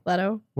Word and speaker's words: Leto: Leto.